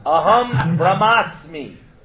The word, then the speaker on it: Aham Brahmasmi: I said it wrong. Oh. Aham brahmasmi.